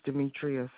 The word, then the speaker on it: Demetria: It's Demetria Scott. – Will you spell that for me? – D-E-M-E-T-R-I-A. Last name Scott, S-C-O-T-T.